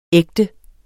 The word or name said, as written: ægte